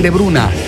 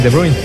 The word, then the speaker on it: De Bruyne: Cuando llega al City, me parece, le preguntan cómo te llamas y dice Kevin De Bruyne.